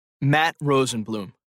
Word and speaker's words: Matt Rosenblum: Matt Rosenblum. – Rosenblum. How do you spell that, Mr. Rosenblum? – R-O-S-E-N-B-L-U-M.